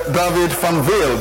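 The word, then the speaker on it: David van Weel: Thank you very much, David Van Wilde. Did I get the name correctly, Mr. Minister? – Absolutely.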